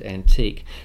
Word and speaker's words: antique: But early on, the key terms are antic and antique. Now, antic and antique are pronounced identically. They both sounded like the modern word antic, and they're spelled interchangeably.